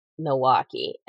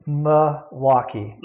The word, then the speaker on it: Milwaukee: You pronounce Milwaukee with an L, which proves that you're not from Milwaukee.